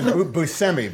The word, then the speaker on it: Buscemi: who will have had Buscemi, by the way. Sorry, we've been Buscemi. Saying it wrong for all these years, who happened to have the only iPhone right where it was supposed to be.